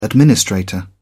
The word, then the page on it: administrator: British English